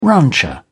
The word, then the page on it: rancher: British English